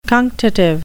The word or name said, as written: cunctative